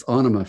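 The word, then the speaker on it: Ænima: That's how Maynard says it. He's the writer. So it's Anima for him. – I've been saying Anima before.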